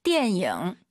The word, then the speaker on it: 电影: 电影. – 이 단어 참 제가 많이 가져오죠, 선생님. – 是的. – 초급자분들께서 띠에닝, 니은 발음으로 많이 해서 그래요. 띠엔잉 잊지 마시고요.